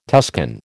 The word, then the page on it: Tuscan: American English